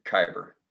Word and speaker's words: Khyber: The first time I saw you was in the, how do you pronounce that? Cyber? Is it cyber? – Khyber. – Khyber.